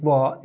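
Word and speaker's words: va (ظ): Now, the same point of articulation is used for the letter va, with the difference that in the pronunciation of va, the root of the tongue or the tongue moves upward, and the pronunciation of va is heavy, much heavier than thal.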